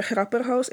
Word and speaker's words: Grapperhaus: Minister Grapperhaus, ik hoop dat ik zijn naam goed heb uitgesproken.